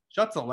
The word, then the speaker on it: Schutzel: But until that point, everyone would be like, Schutzli, I've heard Schlotzky. I mean, it's always fun. It's always fun when people are trying to pronounce German last names. I do the exact same thing because I'm not fluent in German, so. – Schutzel, like pretzel. Yeah.